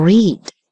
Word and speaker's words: read: To read. To read. Читал. Read. Read. Обратите внимание, пишутся эти слова. Читать и читал одинаково, а произносятся по-разному. Читать.